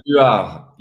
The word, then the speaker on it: Rodrigue: Rodrigue, you are in English, maybe.